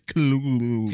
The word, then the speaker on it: Cthulhu: I wake up, ATT, walk my dog, ATT, feed my pet, Chitlulu. Chitluhu? How do you say that word, the weird octopus thing? – Cthulhu? – Cthulhu. You guessed it. – Well, technically, it's Cthulhu.